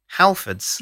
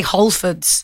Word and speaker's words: Halfords: Halfords. – You don't say Holfords. – No, I say Halfords. – I say Halfords. – But I pronounce the H, unlike you. I just say Halfords.